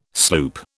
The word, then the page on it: sloop: British English